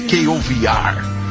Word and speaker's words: KOVR: segundo a emissora de TV KOVR. – K.O.? – K.O.V.R. É a pronúncia em inglês, K.O.V.R.